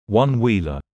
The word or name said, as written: One Wheeler